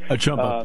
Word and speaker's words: Achumba: Akumba? – Am I saying that right? – Achumba. Uh, Golden Israel Achumba. – Achumba. Achumba. – Yeah, Achumba. Uh, linebacker at Penn State is going to come down and, and sing the national anthem for us.